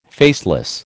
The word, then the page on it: faceless: American English